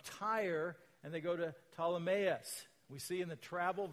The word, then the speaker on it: Ptolemais: We continued on our voyage from Tyre and landed at Ptolemais. You don't pronounce the P in Ptolemais.